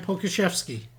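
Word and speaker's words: Pokuševski: I think that's the least of our worries as far as name pronunciation in Oklahoma City moving forward with Alexei Pokushevsky. I don't even know how to say that dude's last name. – No, you nailed it, man. Pokushevsky.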